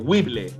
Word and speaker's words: Whipple: Somos chilenos, Whipple.